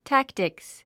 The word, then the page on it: tactics: tǽktiks; タクティクス